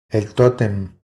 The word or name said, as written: El totem